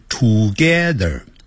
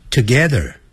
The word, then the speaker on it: together: I live together，不要念成 together，要念 together 那个 T-O-G-E ther 有个 r 结尾，所以在美式的发音呢要卷舌的。